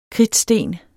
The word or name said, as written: kridtsten